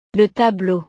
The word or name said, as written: le tableau